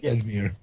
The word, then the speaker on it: Edmure: But yeah, I do think that I thought that they actually struck the right tone with that in his conversation with Edmure. Am I saying that right? Yeah, Edmure, right, Tully? – Edmure.